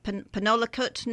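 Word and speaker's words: Penelakut: Penolakut Nation.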